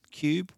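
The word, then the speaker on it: Qube: Now, the LG Cinebeam Cube, it's spelt with a Q, Q-U-B-E. They really have a lend of us with these spellings, but that's them, Cube with a Q.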